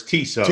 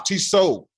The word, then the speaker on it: Tissot: The T. Hey, man, don't you have any culture? The T is silent. – None. – The T on the end is silent. It's Tissot. – I didn't say the T on the end. – I'm just telling you. If you're going to be bilingual like Chuck is, it's Tissot.